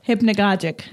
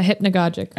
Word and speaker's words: hypnagogic: Hypnagogic. – Yeah, hypnagogic.